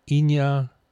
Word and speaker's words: Iñárritu: Alejandro Gonzalez Inarritu, the Mexican, did I say that right? Iñárritu, easy for you to say.